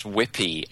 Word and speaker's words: WebP: It's pronounced Whippy, actually. I don't know how they pronounce Whippy, but it says it is.